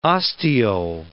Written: ostiole

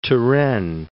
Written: Turenne

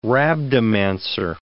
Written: rhabdomancer

